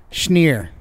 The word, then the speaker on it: Schneer: He said it rhymes with weir. Schneer.